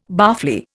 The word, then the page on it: barfly: Indian